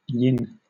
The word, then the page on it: yin: British English